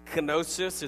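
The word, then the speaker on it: gnosis: The Greek word is kenosis. It's G-N-O-S-I-S, so I guess the G is silent.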